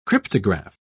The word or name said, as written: cryptograph